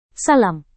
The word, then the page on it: sallam: indonesian